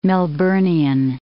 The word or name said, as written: Melburnian